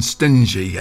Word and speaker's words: Stingly: Proud and Bold, Lee John and Byron Stingy. I think that's how he pronounces his name, S-T-I-N-G-L-Y.